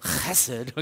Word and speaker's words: chesed: If you really pronounce it right, it's chesed, okay? It's this very guttural kind of chesed is found throughout the Old Testament to describe God.